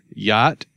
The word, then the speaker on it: Yat: Yacht or yat.